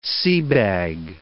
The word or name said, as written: seabag